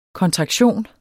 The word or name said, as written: kontraktion